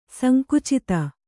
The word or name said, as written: ಸಂಕುಚಿತ